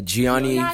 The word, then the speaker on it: Gianni: It was, how do you say, Gianni? Yeah, Gianni Versace.